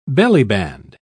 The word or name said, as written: bellyband